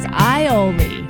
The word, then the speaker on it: aioli: Not an A, it's an I. He says aioli. Oh, boy. Prep, aioli, and Worcestershire.